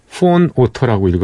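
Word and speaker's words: von Otter: 음, 안네 소피 본 오토 예, 안네 소피 폰 오토라고 읽어야 되겠군요.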